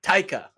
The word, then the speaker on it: Taika: I mean, I feel like Peter Jackson might be excluded from that then, too, because between Takiti and I feel like I'm butchering his name. And Peter Jackson. – Taika. – Oh, wow. Yeah, that was way off.